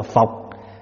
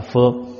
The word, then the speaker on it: phược: là chữ phọc, người Bắc đọc là phọc, mình đọc là phược. Chữ kiết này là chữ kết, này là chữ kết, này là cột. Chữ phược này là mình cũng cột, cột là mình kết, kết là mình đan nó lại, mình cột gọi là kết phược. Người Bắc đọc là phọc. Thí dụ như mình kêu là uế trượt thì người Bắc đọc là uế trọc.